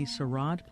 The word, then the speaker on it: sarod: Now, he is the sarod player? – Sarod player, yes. – Sarod, am I saying that wrong? – Sarod.